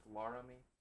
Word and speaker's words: Laramie: How would you pronounce, you mentioned, is it Laramie? Is that how you would pronounce that? – Yes. – Have you ever heard it pronounced Laramie? – Yeah.